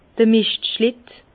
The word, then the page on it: d'r Mischtschlitte: Bas-Rhin; Herrlisheim